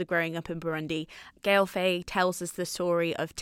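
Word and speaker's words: Gaël Faye: Yeah, Gail Faye.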